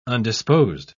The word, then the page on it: undisposed: British English